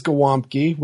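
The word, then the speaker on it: Gołąbki: Poland is Gwomki, which is spelt with letters that don't actually exist. It's spelt, according to Wikipedia, G-O-L with a slash in the middle of it. A with like a little like hangly bit on the bottom. B-K-I.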